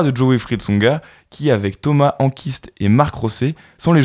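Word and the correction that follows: Enqvist: Thomas Enquist. Hein, euh, Enquist.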